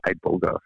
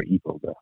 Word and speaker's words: iboga: based off of a plant called iboga from from uh, or iboga, however you want to pronounce it, I B O G A from Africa and it it, it re- helps to rewire the nervous system unlike anything.